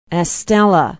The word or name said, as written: Estella